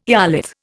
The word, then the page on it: eolith: Indian